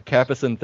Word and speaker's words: Capucin: You put an R in it. – Ah, that was just my accent. I didn't know how to pronounce that C one. – Yeah, Capucin 38 engine.